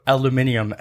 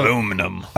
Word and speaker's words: aluminium: How do you say what the shiny metal is? Starts with an A, and they go aluminium, and we just hang up on them. Who says aluminium?